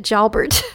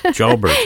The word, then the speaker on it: Jalbert: Pierre Jalbert, I mean, I want to give it the full French pronunciation because of the name. – But he actually calls it Jalbert. – Jalbert, okay.